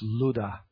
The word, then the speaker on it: Lydda: It's spelled Lydda in the English, but it's actually Ludda and pronounced Luda. Luda.